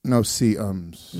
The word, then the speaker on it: no-see-ums: You're saying it wrong. – No-see-ums. – No-see-ums. – They're little... – Because you can't see them.